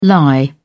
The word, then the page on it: lie around: British English